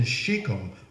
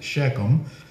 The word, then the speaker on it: Shechem: One time that he spoke to him again was in Shechem, or some people may pronounce it Shechem.